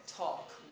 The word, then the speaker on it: talk: It is not talk or walk. Yes? Did you know that? Did you know that? No. Of course. So, or walk. Okay? The L is silent. Okay? Talk, walk.